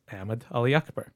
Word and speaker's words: Ahmed Ali Akbar: And I actually had three, Ahmed Ali Akbar. And my parents would say it the Pakistani way, which is Ahmed Ali Akbar.